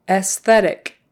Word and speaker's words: aesthetic: Um, just pretend that first A isn't there. Eh, aesthetic, aesthetic. Um, I like the aesthetics of this. Aesthetic, aesthetic. So you do pronounce that S after the E, and it's an S sound, not a Z. S, and then TH, th- th- aesthetic. So STH can be tricky.